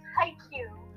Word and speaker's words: Haikyuu: Dad said it wrong. – I always say it wrong. – It's Haikyuu.